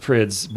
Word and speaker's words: Prydz: Prides. – I always thought it was Prides. Prides. Yeah. – I think it's pronounced Prydz, but I mean, different people pronounce it Pride sometimes.